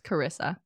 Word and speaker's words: Charissa: My name is spelled with a CH, but it's pronounced Carissa.